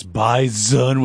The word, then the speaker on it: bison: Also, if you can't listen to that clip without being like, it's bison with a Z, pronounce it like a Z, congratulations, you're appropriately Fargo-fied.